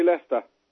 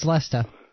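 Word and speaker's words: Leicester: Any of you want to say Lester? – Lester? – Lester? – that's the word the Americans always find tricky to say over here. – Lester? – Lester. – It's Lester.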